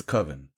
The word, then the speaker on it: coven: Well, now I do. It's coven. – Coven. – That was right.